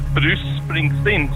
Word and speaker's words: Bruce Springsteen: Det uttales Bruce Springsteen, sånn at nesten sånn at C-en og S-ene er nesten det samme.